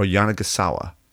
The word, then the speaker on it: Yanagisawa: Yamaha or Yanagisawa? Did I say that right, by the way? Yes. I got the Ian Tordella nod.